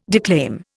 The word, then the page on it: declaim: Indian